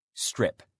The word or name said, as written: strip